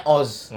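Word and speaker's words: OOO: Yang O-nya tiga tuh. – Hah? – Yang oh, beda Oh, Oh. Dibacanya Oz. Hmm, banyak. Bacanya Oz berarti Iya.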